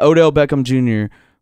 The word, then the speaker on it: Odell Beckham Jr.: You got Odell Beckham Jr., whatever the fuck his name is.